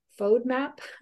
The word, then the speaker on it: FODMAP: Your FODMAP? – FODMAP.